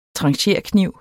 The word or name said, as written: trancherkniv